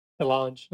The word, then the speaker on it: DeLonge: DeLonge? – DeLonge.